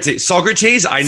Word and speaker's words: Saugerties: How do you say it? – Saugerters, Sausages, New York, some fucking Saug town in Saugerties. New York. Saugerties? Never would have guessed Saugerties.